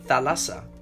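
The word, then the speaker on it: thalassa (θάλασσα): In Greek, the word sea is pronounced thalassa.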